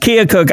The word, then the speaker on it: Keokuk: Anyway, but anyway, again, KOKX 1310 AM and 93.3 FM in the correctly pronounced Keokuk, Iowa.